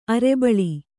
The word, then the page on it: ಅರಿಬಳ: kannada